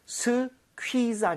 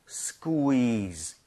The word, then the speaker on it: squeeze: 그리고 발음 주의하셔야 됩니다. 스퀴가 아니라 쿠이로 다 나눠서 하셔야 돼요. 스퀴즈 아니라 squeeze. – squeeze. – squeeze. 대신에 좀 빠르게 부드럽게 연결한다는 느낌이에요.